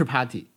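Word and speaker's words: Partey: 但是来之前，所有推特上很多人就说：“ 拜托你们不要太玩谐音梗了、 n o No 胖，因为那个人的名字叫 Party，他叫他叫托马斯帕尔特伊，但是就是 Party，读音就是 Party，不是不是 P A R T Y 啊，拼写有点不一样，但是怎么能不用它去做标题呢？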